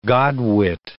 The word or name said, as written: godwit